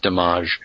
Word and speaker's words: Damage: some people would pronounce Damage because uh, it was directed by Louis Malle,